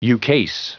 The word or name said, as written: ukase